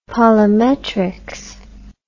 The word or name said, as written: polimetrics